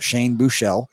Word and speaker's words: Buechele: Shout out to everybody on social media that told me I've been pronouncing his name incorrectly. So shout out Shane Buechele.